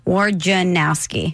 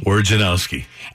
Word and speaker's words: Wojnarowski: Before we dive into that, it's four syllables. Wojnarowski. – Wojnarowski.